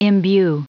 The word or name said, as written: imbue